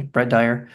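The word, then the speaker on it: Brett Dier: I was particularly, obviously, Brett Dyer. Am I saying that correctly? Brett Dyer.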